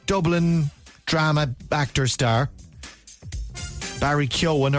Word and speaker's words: Keoghan: Barry Keoghan or Barry Keoghan? – Keoghan. – Keoghan. – I'd say Keoghan.